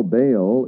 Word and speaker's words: Baal: Well, you know, Baal is a transliterated name from the Hebrew, which is pronounced Baal, or means Lord or Master.